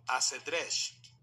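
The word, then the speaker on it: axedrez: Después, en árabe se le solía agregar un artículo al principio, quedando ash shatranj, que el castellano antiguo adoptó como asedresh, pronunciando la X como SH al estilo de aquella época.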